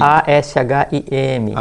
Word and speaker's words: Ashim: Axim, o que? Como é que se escreve o Axim? – A-S-H-I-M. – M. M. – Axim. A-S-H-I-M. É. Anota aí para pesquisar. Beloi, B-E-L-O-I.